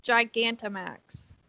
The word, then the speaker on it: Gigantamax: Gynamax. Gentamax. – You'll say it correctly soon. I have faith in you. – Gigantamax.